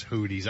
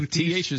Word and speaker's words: Houthi: Is it the Houthis? Is it Houthis? Is it Houthis? – I think it's Houthi. – Houthi, yeah, like the blowfish.